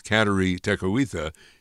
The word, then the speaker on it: Kateri Tekakwitha: You may pronounce it differently. You may have the correct Mohawk pronunciation, but I'm going to go with Kateri Tekakwitha.